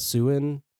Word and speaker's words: Siouan: It is S-I-O-U-A-N. So, like, I guess Siouan, because they're Sioux Indians. – Say that again. S-I-O... – S-I-O-U-A-N. So I know there's S-I-O-U-X, which is Sioux. So I'm assuming that you pronounce it Siouan.